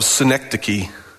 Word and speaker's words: synecdoche: This is a problem that I'm labeling it the problem of synecdoche. Did I say that right? Let me double check. Synecdoche. It's kind of like the city in New York, but it's not.